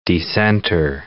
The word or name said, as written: decenter